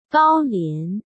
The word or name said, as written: Gao Lian